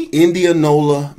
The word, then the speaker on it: Indianola: So you're from a place called Indianola, Mississippi? – Indianola, Mississippi.